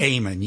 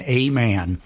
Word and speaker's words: Aman: Amen, yes. – Amen. I'm like, am I saying it right? I got to say it right. – It's just like it's spelled, A-M-A-N, Amen.